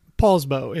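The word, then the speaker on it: Poulsbo: Paulsbow.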